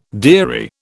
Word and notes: dearie: British English

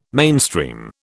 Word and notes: mainstream: British English

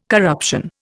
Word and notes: corruption: Indian

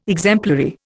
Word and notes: exemplary: Indian

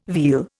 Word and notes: veal: Indian

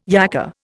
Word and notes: yakka: Indian

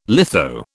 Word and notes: litho: British English

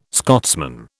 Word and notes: Scotsman: British English

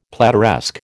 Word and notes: plateresque: American English

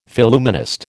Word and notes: Phillumenist: American English

